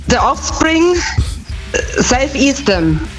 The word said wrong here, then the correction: Self Esteem: Ja, die Aussprache ist ein bisschen. – Steam, he's Steam. – Ja. Ja, ja, ist richtig. – Ja, Self Esteem nehmen wir auch.